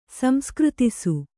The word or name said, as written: ಸಂಸ್ಕೃತಿಸು